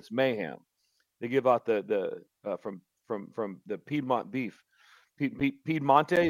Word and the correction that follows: Piedmontese: as I say that right? – Piedmontese. – Yes. Montese, yes. – Piedmontese. You got it. So they're giving away meat?